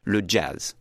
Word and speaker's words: jazz: Anecdotally, the word jazz in French is not pronounced with a French J because everyone knows in France it's an American word. So they pronounce it as if it had an initial D, le jazz. You say le jazz.